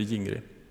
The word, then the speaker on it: Gingri: Det stavas med G. Det är en hel del som uttalar det fel, men det heter Gingri. Och i en av granngårdarna till Olle Blomqvist så bodde det en ungkar.